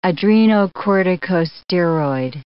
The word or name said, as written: adrenocorticosteroid